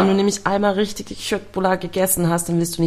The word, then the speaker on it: Köttbullar: Köttbullar.